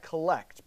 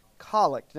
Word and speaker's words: collect: It looks just like collect, but it's pronounced collect.